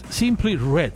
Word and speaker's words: Simply Red: Lo que estamos escuchando se llama It's Only Love y obvio, obvio, es nada más y nada menos que los grandes Simple Red. Simply Red.